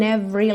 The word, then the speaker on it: every: Every, porque o E do meio dessa palavra não é pronunciada, every line.